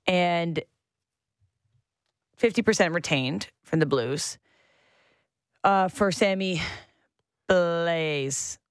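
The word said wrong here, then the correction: Blais: Sammy Blay.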